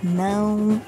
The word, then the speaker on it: não: Okay, one sound that is really difficult to pronounce in Portuguese is actually there are more than one. It's the nasal sounds, like the sounds that you pronounce using your nose, like nasal sounds. So some really common examples are, for example, não and pão, cão.